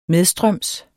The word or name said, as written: medstrøms